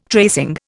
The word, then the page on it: tracing: Indian